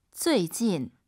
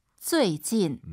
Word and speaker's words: 最近: 最近. – UI 사이에 에 넣으셔야 돼요. 우리 선생님 발음 들어보세요. 쭈이진, 쭈이진이 아니에요. 잘 들어보세요. – 最近.